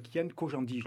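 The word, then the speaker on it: Kyan Khojandi: c'est Kian Kojandi, je le prononce bien.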